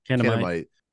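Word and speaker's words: Kanamit: is it Kemenite? How do you say the fucking name? – Kenamite. – Yeah, which I don't know if you guys noticed, sounds very familiar to another word called cannibal.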